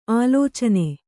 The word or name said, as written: ಆಲೋಚನ